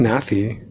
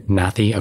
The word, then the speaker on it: Nathy: I think it's pronounced Nathy. – Nathy? Okay, Nathy Peluso. – That would be the Spanish one.